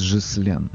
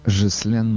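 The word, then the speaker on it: Ghislaine: Она, значит, родилась во Франции. И имя у нее французское. И по-французски ее надо произносить Жислен. Жислен Максвелл.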